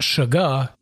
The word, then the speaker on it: sugar: I should also note that it could be pronounced sugar, as we rarely use R's.